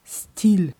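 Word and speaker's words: стиль: Ja, nur mit der Aussprache aufpassen auf Russisch Stil.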